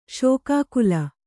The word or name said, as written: ಶೋಕಾಕುಲ